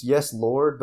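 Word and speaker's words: Yes Lawd!: It's Yes Lord, but it's spelled LAWD with an exclamation point.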